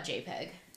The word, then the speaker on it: JPEG: Okay, so most people call that JPEG.